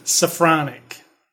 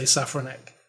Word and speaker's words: Safranek: How do you say his name? Because nobody said his name right. – Yeah. – Safranek, right? – Safranek. – Somebody did say Safranek.